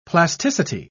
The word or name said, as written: plasticity